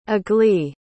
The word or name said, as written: aglae